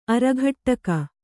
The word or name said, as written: ಅರಿಕಟಕ